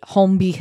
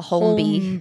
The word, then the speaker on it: Holmby: I think it's quite a hard L. Holmby. – Holmby, not Holmby. – I think they'd say if you're not in a tax bracket to know whether the L is silent or not, then you can't say it.